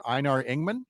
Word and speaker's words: Einar Engman: And you've got a particular anniversary this week for Einar Engman. Did I say that correctly? – That's right. Yeah, you did say it correctly.